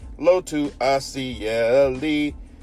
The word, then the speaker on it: Lotu Asaeli: Number 14, Lotu Asaeli out of Trinity High School. I nailed that name, by the way. He's an all-name team member. Name is spelled L-O-T-U. Last name A-S-A-E-L-I. Lotu Asaeli.